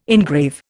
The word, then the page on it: engrave: Indian